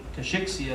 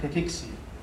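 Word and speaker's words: cachexia: في ناس بيحكوا كشيكسيا لكن هي النطق الصحيح لها كاكيكسيا.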